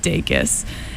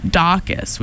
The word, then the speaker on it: Dacus: I recently discovered that her name is pronounced Dacus and not Dacus, which is what I thought it was.